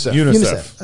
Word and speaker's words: UNICEF: It's going to come here first to UNICEF? – UNICEF. – Is that how you say it? – That's how we say it.